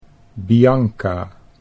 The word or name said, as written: Bianca